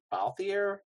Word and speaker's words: Balthier: How do you say his name? Balthier? Yeah, comes on.